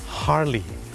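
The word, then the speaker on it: Harley: ฮาร์ลี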